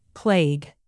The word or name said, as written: plague